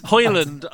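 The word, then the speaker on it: Højlund: Hoyland, I believe is the pronunciation.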